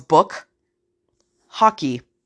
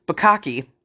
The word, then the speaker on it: bukkake: And the first card I give my mother on Easter morning with my kids all around, because we're not really thinking, was book hockey. So book hockey, like hockey game, book hockey, book hockey. It's bukkake.